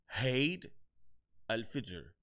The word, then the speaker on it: Eid al-Fitr: Eh, o por lo menos me salió mal, pero al-Fitr. Me dijo eh, Eid al-Fitr. Me dijo un amigo. So, así se pronuncia.